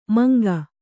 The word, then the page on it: ಮಂಕ: manka